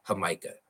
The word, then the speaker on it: jamaica: It's what you make hibiscus tea from, or jamaica, right? Jamaica tea. Am I saying that right? Yeah.